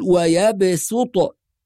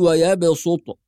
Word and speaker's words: ويبسط: فيكون النطق في هذه الرواية بالسين وليس بالصاد، فنقول ويبسط بالسين ولا نقول ويبسط.